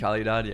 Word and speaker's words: Calidad: Calidad, or how do you say that? Is that how you say it? – Calidad, yeah.